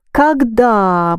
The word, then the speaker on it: когда: You don't pronounce O here, yes? – Yeah. – Когда мы жили на даче, мы часто ходили в лес.